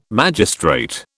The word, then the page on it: magistrate: British English